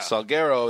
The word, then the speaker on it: Salguero: There was a line from Armando Salguero, is that how you say it? – Salguero,